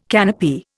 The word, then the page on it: canopy: Indian